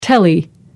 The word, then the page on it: telly: American English